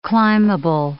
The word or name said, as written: climbable